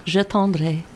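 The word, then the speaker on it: J'attendrai: The next song you have for us tonight, it's called Giatendrai. I'm trying to pronounce that. How do you pronounce it? – Yeah, it's a French song. It's called Je Tendrai.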